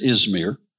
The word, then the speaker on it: Izmir: Hanselman pronounced it last week as Izmir.